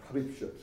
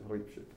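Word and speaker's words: Hripcsak: con un nome più o meno difficile da pronunciare, che, eh, Ripschek si dice, Ripschek,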